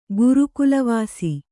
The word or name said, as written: ಗುರುಕುಲವಾಸಿ